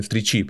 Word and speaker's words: встречи: Ну что, надо тут ударение поставить на встречи, потому что маленьким-то делал там куличи, а сегодня случайные встречи.